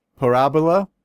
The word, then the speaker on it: Parabola: This is their po- par- parabola. There, I said it right.